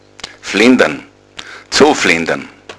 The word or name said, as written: flendern